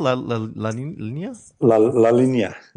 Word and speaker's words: La Linea: How do you pronounce it? La Linea.